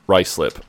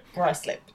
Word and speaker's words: Ruislip: It's on Victoria Road in Ruislip. Am I saying that right? – Ruislip. – Okay. – Ruislip. Yeah.